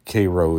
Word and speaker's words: Cairo: Cairo is like Cairo syrup. I'm very close to Cairo, Georgia. And Cairo is probably more official pronunciation in Egypt, but it's Cairo down here. Here, it's like we have Houston County instead of Houston County.